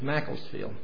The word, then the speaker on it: Macclesfield: I got that pronunciation correct, I believe. Macclesfield. I just checked with Bill this morning to be sure on that one, so I know.